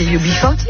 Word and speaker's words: UB40: Moi je ne sais pas, j'avais noté UB40. Mais bien sûr, UB40, voilà. – Oui, c'est UB40.